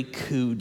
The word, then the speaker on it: coup: Coup Day. Yeah, a coup, C-O-U, silent P.